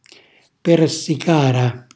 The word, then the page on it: persicara: calabrese